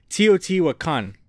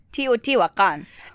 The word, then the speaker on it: Teotihuacan: That's when we went to, and we found out later how to pronounce this properly, Teotihuacan. – Teotihuacan.